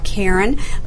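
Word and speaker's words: Caron: So very important, if you just want to know about Karen, let me spell it for you. It's C-A-R-O-N.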